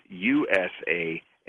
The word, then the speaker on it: Usama: The correct pronunciation phonetically is USAMA.